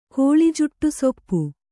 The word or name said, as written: ಕೋಳಿಜುಟ್ಟುಸೊಪ್ಪು